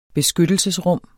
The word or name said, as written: beskyttelsesrum